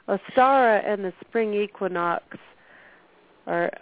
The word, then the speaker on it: Ostara: Is it Ostara or Easter? – No, Ostara. – It is Ostara.